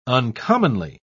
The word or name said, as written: uncommonly